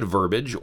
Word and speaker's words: verbiage: No one knows who first said verbiage or why, but we can assume it was the result of confusion with words like garbage, baggage, voyage, wreckage, and postage, each of which is two syllables. When the three-syllable verbiage tried to come out of some people's mouths, it morphed into the two-syllable verbiage.